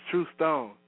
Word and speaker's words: True Stone: Oh, it's True Stone. T-R-U-E and then space Stone.